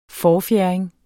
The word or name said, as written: forfjerding